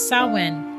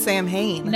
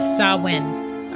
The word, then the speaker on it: Samhain: Wait, how do you say it? – It's Samhain. – Oh, I've always heard it pronounced Samhain. – No, it's spelled that way. But if you pronounce it the way pagans do, it is Samhain.